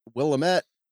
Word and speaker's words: Willamette: I was told as an Australian who often mispronounces people's names to my partner's great chagrin that it is pronounced Willamette, dammit, Janet. So if you like Rocky Horror, that's the way to remember it.